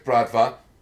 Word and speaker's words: Bratva: Is it Brava? No, it's not Brava. – No, it's Bratva.